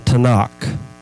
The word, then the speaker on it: Tanakh: So the first appearance of the Satan shows up in the Tanakh.